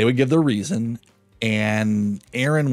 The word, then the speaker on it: ARIN: We're going to be saying Aaron from now on, and it's spelled A-R-I-N.